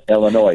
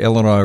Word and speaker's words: Illinois: And that's like the pet peeve of, the S is silent. No, but we're talking noise. – And by the way, we're in Joliet, Illinois.